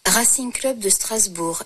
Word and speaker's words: Racing Club de Strasbourg: Racing Club de Strasbourg, RCS.